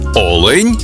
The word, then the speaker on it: олень: І в однині, і в множині наголос падає на перший склад: олень та олені.